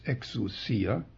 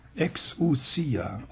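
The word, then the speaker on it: Exousia (ἐξουσία): Also das neutestamentliche Wort für Vollmacht, im Griechischen lautet es Exousia. Wenn Sie mit deutschen Buchstaben schreiben, EXOU SSIA, im Griechischen ist nur ein S, aber es wird scharf ausgesprochen. Exousia,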